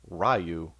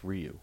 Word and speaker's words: Ryu: In the movie, they call him Ryu, but it's pronounced Ryu.